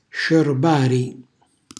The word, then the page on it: sciorbari: Calabrese